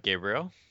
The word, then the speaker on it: Gabriel: Gabriel, but thank you for having me.